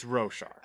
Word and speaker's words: Roshar: So is it Roshar or Roshar? – It's Roshar. – Oh, dang it. I've been saying it wrong this whole time.